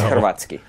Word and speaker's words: Hrvatski: Did I pronounce it well? – It's Hrvatski.